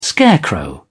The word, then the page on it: scarecrow: British English